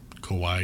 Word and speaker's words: Kauai: Now, on February 20th of 2020, Lori was arrested by the Kauai... Why am I saying that wrong now? – Kauai? – Yeah, sorry.